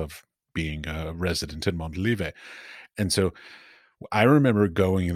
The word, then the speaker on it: Montalivet: Like, because the workshop, in case people who are listening don't know this, the workshop is at, and I'm going to mispronounce it, Montelive? – Montelive, that's fine. That's good.